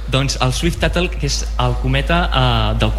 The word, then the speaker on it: Swift-Tuttle: Aquest cometa, el Swift, com es pronuncia? – Swift-Tuttle, que és, ho coneixies, Marc, el Swift-Tuttle.